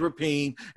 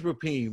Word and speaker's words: Rapien: but it's James Rapien, man, and it's Rapien, man. I just got to learn how to say Rapien.